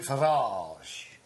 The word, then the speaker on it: Farage: He pronounces it Farage. – And then he says, how do you pronounce the place where you put your car? Garage. – Yeah, garage. My name is Nigel Farage, but I put my car in a garage.